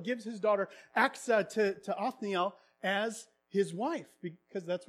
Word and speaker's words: Othniel: Caleb's nephew, Othniel, I think that's how you pronounce his name. Othniel, that's what it is.